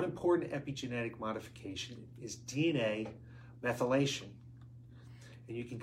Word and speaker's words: methylation: The test, okay, that we talked about is based on DNA methylations levels. Methylation. I don't know why I didn't say that right, but methylation levels.